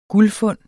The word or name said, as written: guldfund